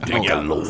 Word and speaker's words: Michelob: Michelob. See, they don't pronounce no, in the South, they don't pronounce, in L.A., they don't pronounce that B at the end of Michelob. It's Michelob.